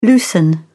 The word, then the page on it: loosen: British English